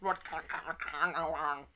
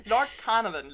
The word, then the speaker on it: Lord Carnarvon: Lord Carnarvon. Lord Carnarvon lernte er kennen.